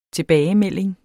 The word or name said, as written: tilbagemelding